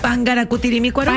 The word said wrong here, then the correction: Parangaracutirimícuaro: Parangaracutirimícuaro. – Parangaracutirimícuaro. Sí.